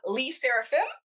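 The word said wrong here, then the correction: LE SSERAFIM: Le Seraphim. – Okay.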